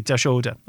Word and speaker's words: Dashoda: Okay, Dashoda.